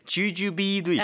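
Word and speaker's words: jujube: 대추 발음이 주주배인 줄 알았는데 주주비였네요.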